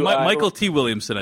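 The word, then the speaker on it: Mykelti Williamson: Mostly with there's a character played by McKelty Williamson. – Yep. – Who Michael T. Williamson, I think, is how he pronounced.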